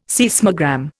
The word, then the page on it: seismogram: Indian